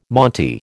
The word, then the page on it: monte: American English